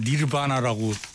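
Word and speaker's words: Nirvanna: 저는 니르바나라고 발음하는 너바나 더 밴드 개봉합니다.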